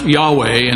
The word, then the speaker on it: Yahweh: And then most often it's pronounced Yahweh, and then Yahweh got corrupted over the years into Jehovah.